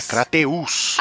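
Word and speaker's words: Crateús: É Crateus, talvez? É isso? Será que é Crateus? – É Crateus. Rapaz. Tem um acento no U ali.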